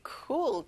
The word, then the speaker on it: cool: 자, O가 두 개라서 U 발음을 길게 빼주세요. Cool. Very cool.